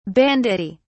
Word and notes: banditti: British English

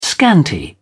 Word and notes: scanty: British English

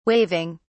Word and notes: waving: British English

